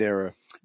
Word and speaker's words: Nyro: I'm only referring to this because anyone who listened to our podcast with Richard Williams knows there's some debate about how to pronounce Laura Nyro's name. – Definitely the, Nero the very first, yeah, the very first line of this review is Laura Nyro, the name rhymes with that of the Roman emperor, has come up with two albums in the last year and a half.